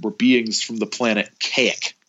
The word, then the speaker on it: Kazik: It's so weird, because in the book he writes it out K-A-Z-I-K and then makes sure to tell you it's pronounced Kaik.